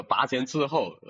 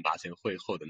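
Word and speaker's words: 跋前疐后: 哦，还有什么拔前滞后？呃，刚刚那个那个我读错的那个，我读成拔前会后的那个，就这个成语特别多。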